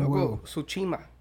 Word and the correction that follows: Tsushima: Tsushima. – Tsushima.